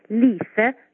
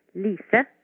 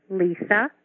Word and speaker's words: Lise: You might be interested to know that the way you would pronounce it in Norwegian is by saying Lise Lunga-Larsen. Remember, Lise Lunga-Larsen in Norwegian, or Lisa Lunga-Larsen in English.